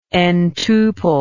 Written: ntuple

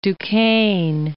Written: Duquesne

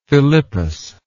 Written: Philippus

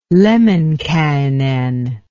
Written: Lemminkainen

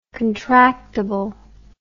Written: contractibly

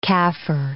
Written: Kaffir

